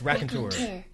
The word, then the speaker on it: raconteur: It's raconteur. – Okay. – Raconteur. – All right. Raconteur. Yeah. Sounds French. – It is French.